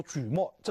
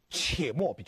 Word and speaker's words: 且末: 我还令敦煌的官员在流沙迎接，因为我们知道到了敦煌，流沙基本上结束，所以我令这些官员在那里迎接，命令善善、且末或者叫举末，这个字是三点水一个而且的且。啊，这古代读音跟今天不同，有好多种读音啊。我是因为根据外文的译音来讲，念且末比较好，不能念沮丧的沮，念沮丧的沮就错了，但是在字典上是沮丧的沮，只能念且末。